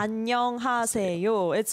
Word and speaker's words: 안녕하세요: 안녕하세요. It's a 5-syllable word.